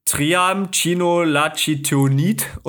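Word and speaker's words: Triamcinolonacetonid: Die Substanz heißt, ich habe keinen Plan, wie das ausgesprochen wird, Triamcinolacetonid oder so. Keine Ahnung, also genauso wird es ausgesprochen.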